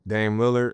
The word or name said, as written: Damian Lillard